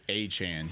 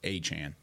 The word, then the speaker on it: Achane: It's Achane. He changed it earlier in the year. It was Achane initially. The NFL put out their whole pronunciation guide. It was a whole thing during the draft, and then he went off for like 200 yards in a game this year, and he's like, oh yeah, by the way, my name is pronounced Achane.